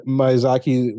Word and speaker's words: Miyazaki: Is a yeah, with uh, Miyazaki, one of his, you know, this is guy's a Japanese animator of great renown.